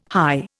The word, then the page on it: hie: Indian